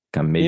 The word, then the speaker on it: Camejo: C-A-M-E-J-O. – Camejo. M-E-J-O. That's my fault. I wrote that down. – Okay. No, but you pronounce the J. It's a hard J. – It's a hard J. Camejo. – Got it. Because it's Portuguese.